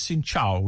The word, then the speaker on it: Xin Chào: And the other word you need to learn is Xin Chào, which is hello. – Xin Chào. How do you say goodbye? – Xin Lo is excuse me, and come on, C-U-M-O-N is thank you.